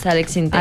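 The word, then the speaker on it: Alex Syntek: Y uno era de Alex Sinté, que usted dijo que se pronunciaba así. Yo sí. Sí, es Alex Sinté.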